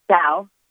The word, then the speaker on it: Kao: Now, your name is spelled K-A-O, right? – That's right. – And you pronounce it how? – Gow. – Gow. – Like G-O-W? – Yes.